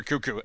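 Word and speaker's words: Koekkoek: The long story short is if you Google how to pronounce Slater Cuckoo's name, there's a funny video of someone intentionally pronouncing it wrong. So when we've looked it up in the past, we've had to double check just to make sure. But it is Slater Cuckoo, and we do expect him to play for the Oilers today.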